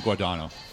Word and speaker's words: Guadagno: Is that how you say her name? – Guadagno? Kim Guadagno.